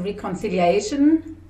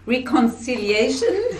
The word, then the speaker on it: Reconciliation: Das ist einmal die Friederike Repnik, Beraterin für das Thema Dealing with the Past und Reconciliation. Oh, ich habe es nicht richtig ausgesprochen. Reconciliation.